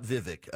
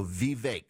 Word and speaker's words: Vivek: So his team said it's a hard E, so it's V, not V, like I've been saying, Vivek. It's not Vivek. It rhymes with cake, so Vivek Ramaswamy.